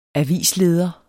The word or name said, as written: avisleder